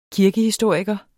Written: kirkehistoriker

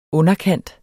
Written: underkant

